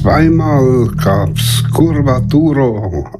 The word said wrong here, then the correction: Curvatura: aus Spanien. Ja, mein Curvatura, so wäre das dann richtig ausgesprochen.